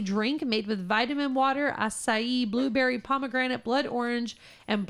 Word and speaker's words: acai: This acai? – That's how you say it. – It's acai, isn't it? – No, okay. – Acai. – All right, sorry.